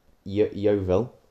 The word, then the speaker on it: Yeovil: Maidstone are currently 6th in the National League South, and that includes teams such as Yeovil.